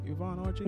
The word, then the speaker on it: Yvonne Orji: That's how you pronounce her name? Yvonne Orji? – Yes. Yes.